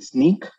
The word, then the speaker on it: Snyk: Is it Sneak? S-N-Y-K? – It is.